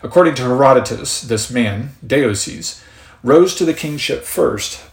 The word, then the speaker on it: Deioces: That is written out D E I O C E S, Deioces. That's the best pronunciation you'll get from me on that.